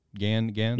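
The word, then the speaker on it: Gangan: Uh, the creator of uh, Full Metal Alchemist will be having a new series in Shonen Gengen. is that how you pronounce it? Gengen. Gan Gan. – Yeah. Gan sure. Gan.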